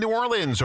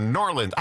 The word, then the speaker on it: New Orleans: He's in New Orleans or Norlands. I heard him say Norlands the other day. That's what the locals say, Norlands.